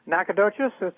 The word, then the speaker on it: Nacogdoches: Nacogdoches. – Nacogdoches. There you go. – It's a Caddo Indian word. – Yep. – And the G is what throws people off. Everybody thinks it's Nacogdoches, but it's Nacogdoches.